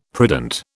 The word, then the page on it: prudent: British English